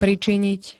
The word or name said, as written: pričiniť